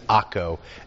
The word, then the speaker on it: Akko: And this Ptolemais, that's Akko, A-K-K-O. That's its historic name. Its modern name is Akko, A-K-K-O. I'm going to call it Akko. Sometimes it's spelled in Bibles A-C-R-E, but it's Akko, okay?